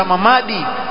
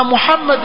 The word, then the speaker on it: Muhammad: Ndio yule mmoja wao akasema Muhammad nikimwita Mamadi ndio ashakuwa Mburamadi. Akasema hivyo, Muhammad nikimwita Mamadi ndio ashakuwa Mburamadi. Ah, Muhammad ni Muhammad vile vile, usiuite Mamadi, utakuwa mbadilisha lile jina, halikutamkwa sawa sawa, sio Muhammad Muhammad.